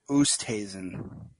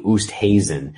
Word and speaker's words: Oosthuizen: Oosthuizen. – Thank you. Oosthuizen.